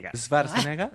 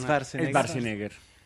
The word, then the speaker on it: Schwarzenegger: Pero amiguito, mire, es Schwarzenegger. – Para nosotros nos enseñaron. – Schwarzenegger. – Ah, sí. Pero entonces todos los doblajes de las películas suenan súper mal. – Mal, todos mal pronunciados.